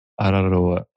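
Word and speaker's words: Te Araroa: Te Te Araroa. Araroa. – Yeah, it's it's the rolling of the R's that is quite hard.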